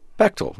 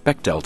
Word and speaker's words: Bechdel: It's Bechtel, rhymes with rectal. – So I submit that for the record. – Nice. – Not Bechdel test. Bechdel test.